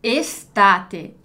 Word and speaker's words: estate: Então, cuidado aqui, para não ter essa pronúncia do S mudo, estate, né? Estate, estate, que tem um E mesmo. Então, aqui a gente pronuncia todas as letras.